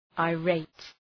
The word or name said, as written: irate